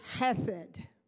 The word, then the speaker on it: hesed: And the word is hesed. Hesed.